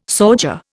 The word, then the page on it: sauger: Indian